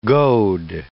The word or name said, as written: goad